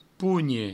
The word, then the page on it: ponhe: pougne; provençal